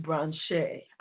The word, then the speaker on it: Branché: And then we have the stunningly gorgeous and very, very sexy Miss Stacye Branche. I believe that's the way you pronounce her name.